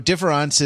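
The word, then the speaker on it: différance: He changes the letter E to the letter A in différence. It's not spelled difference, it's spelled différence with an A, but it's pronounced the same in French.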